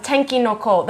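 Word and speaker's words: Tenki no Ko (天気の子): Tenkai no Ko? – Yeah, Tenki no Ko. That's actually the name of Weathering With You in Japanese, and it literally translates to weather children, like the children of the weather.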